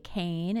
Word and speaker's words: Cain: My Twitter name is underscore Holly Cain, and Cain is spelled C-A-I-N. So it's underscore H-O-L-L-Y-C-A-I-N.